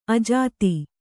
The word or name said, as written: ಅಜಾತ